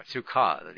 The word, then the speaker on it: sukkah: Yeah, sukkah, the accent in the second syllable.